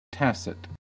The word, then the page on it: tacit: American English